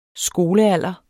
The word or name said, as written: skolealder